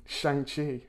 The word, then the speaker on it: Shang-Chi: Next one up, Tim, Shang-Chi, as it's now correctly pronounced, if you were to believe Kevin...